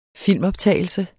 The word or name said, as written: filmoptagelse